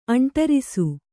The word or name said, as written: ಅಂಟರಿಸು